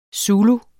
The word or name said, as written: zulu